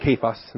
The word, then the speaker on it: Cephas: They pronounced it Cephas in the Aramaic.